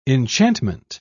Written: enchantment

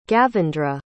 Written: gavendra

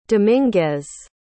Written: dominguez

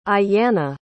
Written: aiyanna

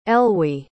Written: elwy

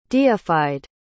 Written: deified

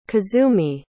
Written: kazumi

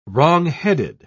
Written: wrongheaded

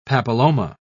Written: papilloma

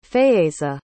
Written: Faeyza

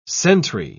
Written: sentry

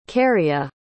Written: kerrya

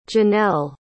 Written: Janelle

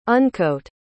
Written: uncoat

